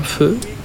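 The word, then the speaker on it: phở: Il y a partout des terrasses, de trottoirs et des petits tabourets en plastique où l'on s'assoit pour un feu, ça s'écrit faux en français, mais ça se dit feu en vietnamien.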